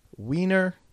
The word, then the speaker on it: Weiner: If it was I-E, like Matt Weiner, the producer of Mad Men and a bunch of other shows, well, there you go. It's pronounced E. Get it? I-E, it's Weiner.